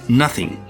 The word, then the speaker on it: noting: It could have also been called There's Something About Noting, or N-O-T-I-N-G, although the Elizabethans still pronounced N-O-T-I-N-G as nothing. But noting, N-O-T-I-N-G, meant to gossip, rumor, or overhearing.